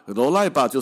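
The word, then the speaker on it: 罗 赖 巴 (ドライバー): 就是你去球场上听来讲，哎，罗赖巴吼，啊那罗赖巴边那帕，罗赖巴就是 driver 的日文发音呢。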